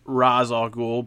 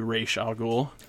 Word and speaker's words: Ra's al Ghul: In this scenario, he goes up against some members of the Light, chiefly Lex Luthor and Ra's al Ghul, as his name is pronounced in this iteration. I'm one of those people. I tend to pronounce it Ra's al Ghul, but I can certainly adapt to Ra's al Ghul.